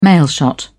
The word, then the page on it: mailshot: British English